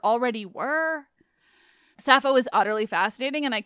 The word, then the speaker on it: Sappho: Also, I'm just going to be a nerd and share that in modern Greek, it's pronounced Sappho, which I love.